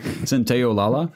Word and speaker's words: Santaolalla: Santaolalla.